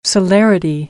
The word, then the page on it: celerity: American English; ɾ